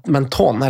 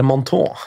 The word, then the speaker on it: Menton: Så var vi i en liten by som heter Menton, eller Manton, uttales vel.